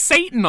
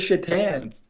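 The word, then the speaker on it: Šatan: As it says Satan on the back. – It says Shatan. – Well, it's spelled Satan.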